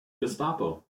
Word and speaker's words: Gestapo: Gestapo. – There you go. – That would be Gestapo. – I knew I was saying that wrong.